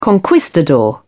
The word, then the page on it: conquistador: British English